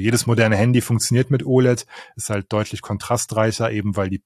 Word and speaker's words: OLED: Wird das OLED ausgesprochen? Ich weiß gar nicht. – Ich sag immer OLED, ja.